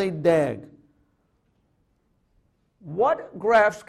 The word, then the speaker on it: DAG: A directed graph that contains no cycles is called a directed acyclic graph, which we call pronounce as a DAG.